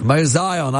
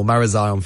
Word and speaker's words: Marazion: Marazion. – I always wonder. I've been there and I always wonder how it's pronounced. And I've learned something today. Marazion, forgive me.